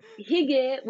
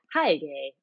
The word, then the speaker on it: hygge: But yes, hygge, which I did not know that's how you pronounced it. I'd seen it only like written about in design blogs, and I think I said hygge or something.